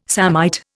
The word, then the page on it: samite: Indian